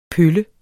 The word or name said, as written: pølle